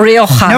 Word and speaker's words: Rioja: Somebody actually pronouncing Rioja correctly on Movies and Booze. What's the word coming to? – That's because my co-host of the podcast, Wine the Long and Short, is Antonia Dominguez and she's Spanish. And if I don't say it correctly, I get in trouble.